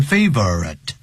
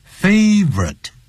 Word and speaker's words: favorite: 对谁呢 ?to two of our favorite, 不要念成 favorite, favorite, 我们两个最喜爱的那么这种职员、员工一位是 Harper, 另外是 Joseph。